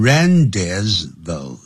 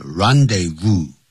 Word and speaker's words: rendezvous: 那这次演讲比赛的时候他得了第一名 First place, second place 也是一样的前面都不能加的 The Moulin Rouge was the rendezvous、啊、这个时候不能念成 rendezvous 那不是英文不是英文是 rendezvous 哎，